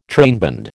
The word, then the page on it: trainband: American English